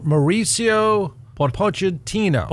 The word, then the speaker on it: Pochettino: Prior to, how do you pronounce his name? Pochettino? – Mauricio Pochettino.